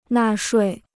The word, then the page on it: 纳税: nà shuì